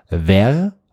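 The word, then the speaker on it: verre: Ver, V-E-R-R-E, e, bedeutet Glas. Und das Spannende, es gibt ein zweites französisches Wort. Das spricht man eigentlich genauso aus, auch ver, und das schreibt man aber V-A-I-R, und das bedeutet Eichhörnchenfell.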